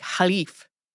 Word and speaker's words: caliph: I feel like we have always said this either as caliphate or caliphate on the show. They take a more Arabic pronunciation in this video game, and so it's always like Khalif, the Khalifate.